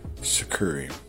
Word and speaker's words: tsukuri: It's kumikata, kazushi, sukuri, and kake. You spell kumikata K-U-M-I-K-A-T-A. Kazushi is spelled K-U-Z-I-S-H-I. Sukuri is spelled T-S-U-K-U-R-I. And kake is spelled K-A-K-E.